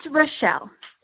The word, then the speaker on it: Rashel: Is it Rachelle Pack? It's Rochelle. Rochelle. Okay, super. – Yep. It's one of those tough names. There's 101 ways to spell it. I know. And then my parents had to be weird and spell it like phonetically. But then if you look at it, it looks really close to Rachel.